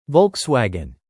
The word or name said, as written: Volkswagen